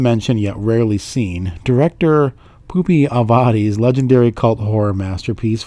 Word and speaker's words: Pupi: I don't think it's Poopy. I think it's Pupi.